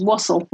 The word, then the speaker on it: Wassell: Oh yeah, mine is Wassell, like waffle.